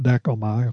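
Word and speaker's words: dekomai (δέχομαι): We should note here that the original Greek word for receive, it's pronounced dekomai, if that's important to anybody, connotes to welcome.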